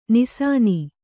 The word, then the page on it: ನಿಸಾನಿ: nisāni; Kannada